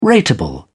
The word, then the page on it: rateable: British English